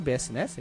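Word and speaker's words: bsnes: Ele funciona no BS NES, falei certo? BS NES, é isso? Isso? Isso.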